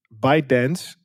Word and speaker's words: ByteDance: Is dat ByteDance, en dat is de maker van TikTok, als ik het goed zeg.